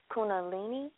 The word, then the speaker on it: Kundalini: it's Kundalini? – Right.